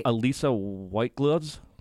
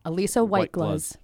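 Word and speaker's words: Alissa White-Gluz: Elisa White Gluz. – Elisa White Gluz. – Did we get that right? – Yes, Elisa? That's right.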